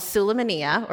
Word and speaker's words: Sulaymaniyah: It was called Sulaymaniyah, or as the locals say, Sulaymani.